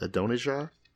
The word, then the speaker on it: Adonijah: Adonijah? – You got it.